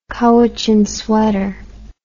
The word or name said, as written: Cowichan sweater